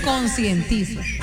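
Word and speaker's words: concientizo: Yo sé que no hay luz, me consentizo, que no hay luz. – ¿De qué? – Me consentizo. Padre. – Consentizo no se dice, se dice conscientizo.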